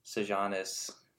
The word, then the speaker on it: Sejanus: His family's out of wealth, and his friend Sejanus, that's how you say his name.